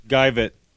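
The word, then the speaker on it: Geivett: How do you say it? Grevitt? – Guyvett. – Guyvett, that's it.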